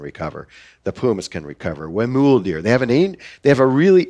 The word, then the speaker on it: Huemul: Yeah, Huemul is how they pronounce it.